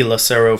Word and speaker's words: Lucero: Lucero Photos, and that's my last name is spelled L-U-C-E-R-O.